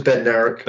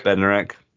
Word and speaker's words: Bednarek: It was Bednarek. – Bednarek, okay.